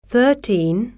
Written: thirteen

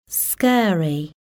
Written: scary

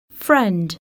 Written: friend

